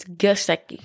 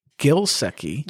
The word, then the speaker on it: Gesicki: Yeah, Mike Gesicki. – Whoa. – No, it's Gesicki. G-E-S.